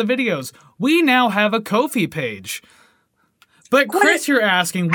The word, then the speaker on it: Ko-fi: Anyways, that's how they say a cup of coffee. But anyways, it's pronounced Ko-fi.